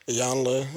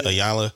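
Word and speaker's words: Iyanla: Ayanla? – Yeah. – Ayanla. – Yeah, Ayanla, mm-hmm.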